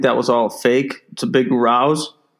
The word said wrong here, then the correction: ruse: Yeah, that's how you say it. Yeah, rouse. – Ruse.